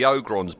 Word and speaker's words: Ogrons: I've got to pronounce the O. The Ogrons board and the Doctor's shot.